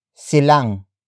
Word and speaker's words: silang: Word for word it's, ilan, silang, pupunta, sa, protesta.